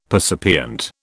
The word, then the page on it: percipient: British English